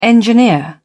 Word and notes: engineer: British English

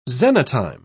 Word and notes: xenotime: British English

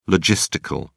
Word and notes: logistical: British English

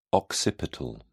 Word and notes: occipital: British English

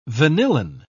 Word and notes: vanillin: British English

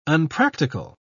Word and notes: unpractical: British English

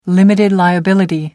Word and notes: limited liability: American English

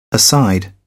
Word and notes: aside: British English

